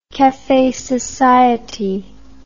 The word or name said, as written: cafe society